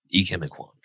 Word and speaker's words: Ikem Ekwonu: Or, come on, let's pronounce his name. Ikem Ekwonu. Ikem Ekwonu. – Good job.